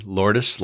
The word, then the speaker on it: Lourdes: And Lourdes Calclo, I'm saying that correctly. Lourdes, like Madonna's daughter.